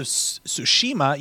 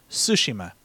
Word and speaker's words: Tsushima: It's not Ghost of Tsushima. You put the wrong emphasis on the wrong syllable when you say it that way. It's Ghost of Tsushima.